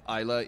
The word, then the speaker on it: Islay: Isla is spelled I-S-L-A-Y, mm-hmm.